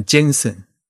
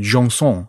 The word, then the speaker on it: Jenson: 之前我们肯定在讲过啊，尼古拉让松他本人是法国人，但是呢他主要的工作呢，这主要的活动时间他一直都是在意大利啊，所以很多人会把他的名字念成那个什么 Jensen。啊 y a n s e n 或者之类的。但是因为他是法国人，应该叫嗯 Jean Song 啊，应该翻成让松啊，尼古拉让松，他是一个法国的刻字师。